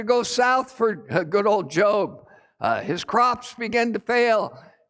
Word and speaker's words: Job: His name is spelled J-O-B. It's not Job, it's Job. And Job, you know, as you read his story, he thought he had God pretty figured out.